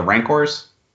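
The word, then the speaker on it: Rancor: With all that, Mando goes to his destination, and the creatures he's attacked by, doesn't it almost looks like the, I'm going to fuck up the name, the Rancors? Did I fuck that up? – No, that's how it's pronounced.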